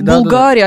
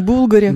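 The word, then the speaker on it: Bulgari: Булгари, да. А не Булгари. – Да-да-да. Булгари, да? – Булгари, да. Ну, в Италии, как правило, ударение падает на предпоследний слог.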